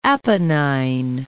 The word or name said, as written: Apennine